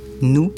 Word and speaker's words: noue: Là, nous, ça s'écrit nous, N-O-U-E.